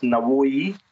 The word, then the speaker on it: Navoi: The way it works is somehow there's a taxi service that only goes to a town that's about halfway, pronounced Navoi, who it's a city named after one of their poets, philosophers, the Soviet era city.